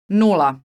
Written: nula

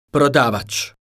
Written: prodavac

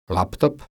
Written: laptop

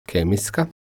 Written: kemijska

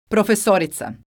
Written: profesorica

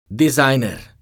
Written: dizajner

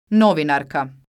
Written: novinarka

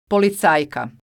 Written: policajka

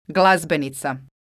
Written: glazbenica

